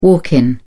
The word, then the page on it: walk in: British English